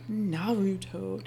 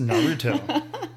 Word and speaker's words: Naruto: Naruto. Did I say it right? – You said it right. Yay! That time. It is Naruto.